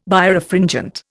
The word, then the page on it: Birefringent: Indian